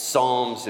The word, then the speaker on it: Psalms: It's Psalms. It begins with a P. The P is silent.